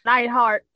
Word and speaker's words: Neidhart: Neidhart.